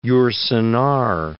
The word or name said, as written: Yourcenar